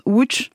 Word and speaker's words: Łódź: Alors, rien ne m'étonne, sauf Łódź, qu'on prononce Łódź en polonais.